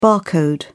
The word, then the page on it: bar code: British English